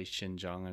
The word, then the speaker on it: Xinjiang: I'm like a visual person, and so when you say Xinjiang I'm thinking like S-H-I-N, but X.